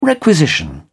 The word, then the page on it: requisition: British English